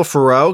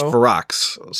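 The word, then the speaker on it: Ferox: Which I want to pronounce Cannibal Farrow because it feels like that makes more sense. But everyone says Farrox. So who said it was Farrox? – Like everybody, if you watch like a any video on it, that's how they pronounce it.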